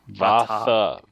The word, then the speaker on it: Vathah: Are Vatha two of and Ishna, yes. – Yeah, they're two of Shallan's light weavers. Or yeah, I think Vati is. – Vatha. V-A-T-H-A-H.